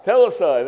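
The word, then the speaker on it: Telestai (τετέλεσται): I'm butchering that Greek word, but tetlestai. Telestai, there we go.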